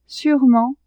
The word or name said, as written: sûrement